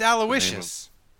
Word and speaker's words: Aloysius: Oh, that's Aloysius.